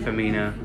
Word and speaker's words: Firmino: Firmino.